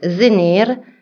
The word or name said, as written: bulan Januari